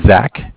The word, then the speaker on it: Zach: My name is Zach, spelled Z-A-C-H. – Mm-hmm. – I'm calling with Bank of Cardiff. Cardiff is spelled C-A-R-D-I-F-F.